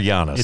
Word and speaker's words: Giannis: So first of all, is it Giannis or Giannis? – It's Giannis. – Okay. Whatever. I've heard people mispronouncing this all week. Like even on ESPN, they're like Giannis. And I'm like, I don't think that's how you say his name.